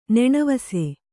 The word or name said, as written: ನೆಣವಸೆ